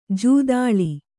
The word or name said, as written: ಜೂದಾಳಿ